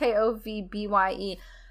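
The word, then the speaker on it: Skovbye: So Nikki is played by Tara? Scoffby? I don't know how to say her last name. Yeah. It's Scovbye.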